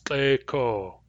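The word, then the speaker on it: Kleco: For now, I'll just point out that it has two long vowels. Kleko. Kleko. It's one of the most common words that people who don't speak Nuchanalth are likely to know, so it gets mispronounced. You might have grown up speaking, pronouncing it the English way, in which case you'll have to unlearn the English pronunciation in order to pronounce it the Nuchanalth way.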